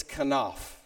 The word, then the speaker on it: kanaf: It's the word kanaf. That's the Hebrew word. I pronounce it that way. I could say it five different ways. You wouldn't know the difference. It's kanaf.